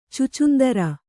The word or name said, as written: ಛುಚ್ಚುಂದರ